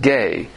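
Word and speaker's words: gay (γῆ): Usually the word for world is G-E, pronounced gay, which means the world.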